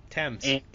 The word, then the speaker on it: Thames: Thames. Thames is how it's pronounced, apparently. – Thames, I'm sorry. Eric Thames. Is that right? Thames? – That is what I hear.